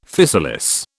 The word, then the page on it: physalis: British English